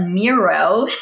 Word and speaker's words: Miro: I'm nothing against Mural, but Miro rhymes with hero.